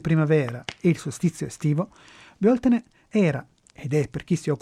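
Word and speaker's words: Bealtaine: Beltane, in pronuncia anglicizzata, o Bioltene in irlandese moderno, che significa maggio, è la festa del fuoco luminoso, come ci ricorda l'etimologia del nome.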